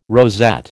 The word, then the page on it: rosette: American English